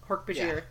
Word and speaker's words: Hork-Bajir: And Jake says that Chapman was the guy telling the I forgot the pronunciation. Hork-Bajir? – Hork-Bajir, yeah. – Okay, yeah. For some reason, I left out the J in my notes in Bajir.